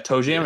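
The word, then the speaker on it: ToeJam: How do I pronounce that? Toe Jam? Yeah, Toe Jam and Earl.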